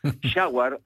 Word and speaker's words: Jaguar: A lo cual Frank Lowe le dice, lo primero que tenés que hacer es aprender a pronunciarlo. Jaguar es la ducha. Esto es Jaguar.